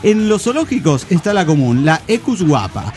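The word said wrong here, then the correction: quagga: ¿Guapa? Ah, no, cuaga.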